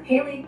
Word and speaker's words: Halley: According to Wikipedia, there are three pronunciations of the surname Halley. These are Halley, Helly, and Lee. As a personal surname, the most common pronunciation in the 21st century, both in Great Britain and in the United States, is Halley.